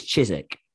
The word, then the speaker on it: Chiswick: We were in this place called Chiswick, but apparently it's Chiswick.